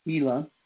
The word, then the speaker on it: Gila: He was apparently not briefed ahead of time on how to pronounce the Gila and the Washoe rivers, pronounced Gila, H-E-L-U-H, and Washoe, W-A-S-H-O, is the way they got it pronounced, respectively.